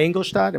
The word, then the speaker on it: Ingolstadt: Was it Die Ingolstadt? Am I saying that correctly? – Yes.